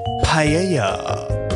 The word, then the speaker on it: paella: That's the correct way to say it, by the way. – It's not paella. – Paella? No, paella. – I like paella. – Yeah, it's like three different vowel sounds all in the same time.